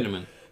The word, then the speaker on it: Tiananmen: It seems many countries were going through a lot of riots and protests, and one of the most famous ones is at, how do you say it, right, Tiananmen Square, or am I saying that? Mm-hmm. I'm not saying that right, probably. – Tiananmen.